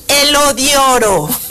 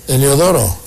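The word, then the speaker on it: Heliodoro: Claro que sí, hoy estamos festejando a Tomás Apóstol, Anatolio y Elodioro. Muchas felicidades. – Eliodoro.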